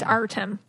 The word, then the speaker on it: Artem: I don't want to mispronounce this guy's name. – It's Artem.